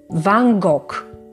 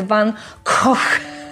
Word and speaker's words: van Gogh: Popatrzcie, większość z nas, zarówno dyletantów, jak i znawców tematu, sugeruje się zapisem i wymawiamy je tak, jak się je pisze po polsku, czyli Van Gogh. Ale nie jest to sposób zgodny z oryginałem, bo w Holandii, a więc w kraju, z którego Vincent pochodził, głoskę G wymawia się jak H w dodatku jest to takie ha bardzo twarde, harczące. Brzmi niemal jakbyśmy się krztusili, coś w stylu Vincent van Koch.